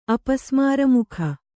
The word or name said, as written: ಅಪಸ್ಮಾರಮುಖ